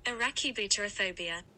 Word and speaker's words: arachibutyrophobia: Arachabutyrophobia. Yeah, let me Google it. Okay, I'm just going to have Google tell you how it's pronounced. I hope you can hear her. – Arachibutyrophobia.